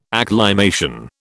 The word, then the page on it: acclimation: British English